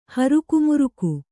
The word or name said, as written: ಹರುಕುಮುರುಕು